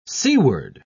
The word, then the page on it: seaward: British English